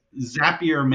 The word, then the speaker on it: Zapier: Since their phrase is Zapier makes you happier, I assume it's Zapier, but you know.